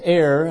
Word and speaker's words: err: We find that the word err, and I know that's pronounced err by some, but the word err here is used in a sense of wandering from the right path or from the truth of God's word.